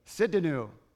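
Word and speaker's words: Tsidkenu: How do you say this one? Yeah. Anybody got that one? Sidkenu. Sidkenu. Very good.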